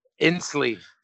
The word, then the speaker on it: Inslee: I don't know how to say his name. Jay Inslee. Sounds, it's I-N-S-L-E-E.